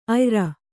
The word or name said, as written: ಆಯಾರ